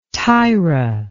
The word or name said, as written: tayra